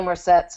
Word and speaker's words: Marcet: Something that was in the Cosmos app about Faraday was one of his greatest early influences were books by Jane Marcet, probably Marcet since it's English and they always pronounce the T, like valet, Conversation in Chemistry.